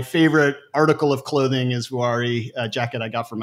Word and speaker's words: Vuori: Am I pronouncing it correctly? I've been wearing the clothes for a long time, but I don't think I've actually pronounced it. – Yeah, so Vuori is the best way that I can say to say it.